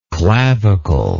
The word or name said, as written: claviculate